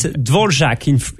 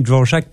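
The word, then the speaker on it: Dvořák: Mendelssohn, Hear My Prayer, Dvorak, am I pronouncing his name right? – Normally it's Dvorak. In France, we pronounce it Dvorak, but maybe in the US you say Dvorak.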